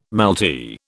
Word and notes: malty: British English

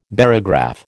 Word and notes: barograph: American English

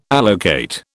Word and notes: allocate: British English

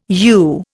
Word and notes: you: Indian

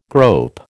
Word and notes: grope: American English

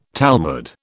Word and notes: Talmud: British English

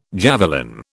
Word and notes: javelin: British English

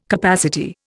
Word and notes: capacity: Indian

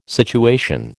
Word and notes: situation: American English

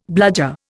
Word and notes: bludger: Indian

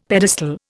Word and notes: pedestal: Indian

